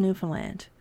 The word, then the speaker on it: Newfoundland: The first scene is Bourdain asking how to properly pronounce Newfoundland. It's like, understand, didn't help him, and that's obvious as he continues to say Newfoundland during the show.